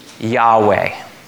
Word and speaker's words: Yahweh: And because the Hebrew language is only in consonants, no vowels, we don't know exactly how to pronounce this name that God gave us. It's the consonants YHWH. And most scholars have agreed that they think it's probably pronounced Yahweh.